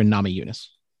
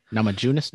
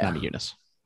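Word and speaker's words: Namajunas: Is it it's a soft J? – Yeah, Nama Yunus. – Give you a soft J. – I always thought it was with a hard J. – Nama Junus? No, it's Nama Yunus.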